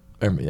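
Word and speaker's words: Hermès: Hermes. Yeah, that's right. – Yeah. Okay. – Yeah. That's like the H belt, right? – Yeah. – Yeah. – They have it was Hermes.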